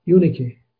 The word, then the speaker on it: Eunice: I think that's how it's pronounced in the Greek. Eunice.